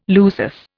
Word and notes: lusus: Indian

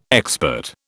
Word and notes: expert: British English

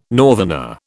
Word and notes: northerner: British English

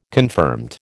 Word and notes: confirmed: American English

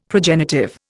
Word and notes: progenitive: Indian

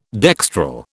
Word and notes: dextral: British English

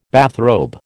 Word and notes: bathrobe: American English

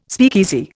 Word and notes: speakeasy: Indian

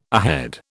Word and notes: ahead: British English

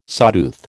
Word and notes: sawtooth: American English

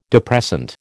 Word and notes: depressant: American English

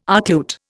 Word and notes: archlute: Indian

